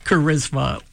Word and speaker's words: charisma: Our challenge to you is to come up with five other words that use CH as their first two letters, but in their pronunciation, the CH is pronounced as a K, just as in chorus and charisma.